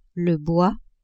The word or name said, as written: bois